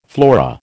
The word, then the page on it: flora: American English